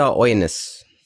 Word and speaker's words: Ønæs: Jannecke Ønæs.